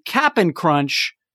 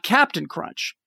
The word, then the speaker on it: Cap'n Crunch: But now in the sweet tweet, he's saying, actually, no, I think I'm more on the side that it should be Cap'n Crunch, not Captain Crunch.